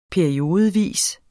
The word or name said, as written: periodevis